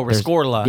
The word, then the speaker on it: Rescorla: And it's, I don't know how you pronounce it, but it's R-E-S-C-O-R-L-A. – Rescorla. – Rescorla. – Oh, Rescorla. – Yeah, there's an R. Rescorla.